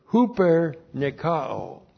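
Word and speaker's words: huper nikao (ὑπερνικάω): In all these things, and then the verb huper nikao. Huper nikao. H-U-P-E-R-N-I-K-A, long O.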